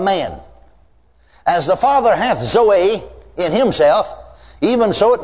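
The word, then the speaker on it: Zoe (ζωή): It is pronounced Zoe. Now I'm not a Greek scholar. But the professor of Greek in Baylor University, in Waco, Texas Baptist Seminary, told me that that's the way you pronounce it, is Zoe.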